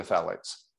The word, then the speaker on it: phthalates: So we should probably get back to what I want to get back to that, but real quick, because you talk about the thiats. Is that correct? Am I saying it correctly? The thiats, the plastics. – Oh, phthalates.